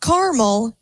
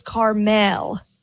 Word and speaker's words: Carmel: It's not caramel. It's Carmel.